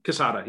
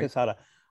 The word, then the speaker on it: Quesada: I always Quesada, yeah, that's right. – Quesada. I was almost about to say Quesadilla.